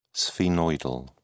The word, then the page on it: sphenoidal: British English